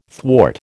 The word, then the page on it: thwart: American English